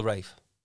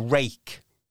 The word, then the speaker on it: wraith: Are you saying wraith or rake? – Not saying rake. I'll spell it for you. – Yeah. – It is W-R-A-I-T-H. Wraith.